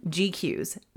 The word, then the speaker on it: GQueues: So app number one, GQs. This is my to-do list. It is spelled GQUEUES. There will be a link in the show notes.